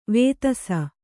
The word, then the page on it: ವೇಧಸ: Kannada